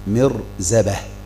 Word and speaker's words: مرزبة: نعم، سؤال شيخ الإسلام عن الذبيح، فائدة في المصباح المنير، الإرزبة بكسر الهمزة مع التثقيل، قال الإرزبة والجمع أرازب، وفي لغة مرزبة بميم مكسورة مع التخفيف، يعني إن نطقناها مرزبة نقول هكذا مرزبة، لكن احنا ننطقها ايه؟ مرزبة. قال والعامة تثقل مع الميم، العامة تثقل مع الميم. قال ابن السكيت وهو خطأ، والجمع مرازب بالتخفيف، يبقى فيه فرق اذا من الارزبة دي اللي هي مشددة والمرزبة، يبقى مرزبة هذا بالتخفيف، يبقى هنا هيبقى عندنا ايه؟